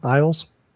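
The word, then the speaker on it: Illyes: Is that how you pronounce his name? Iles? – That's what I call.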